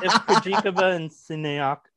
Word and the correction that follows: Siniakova: I'm so sorry, Siniakova.